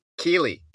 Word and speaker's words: Keighley: I believe that's how you say it. Keighley.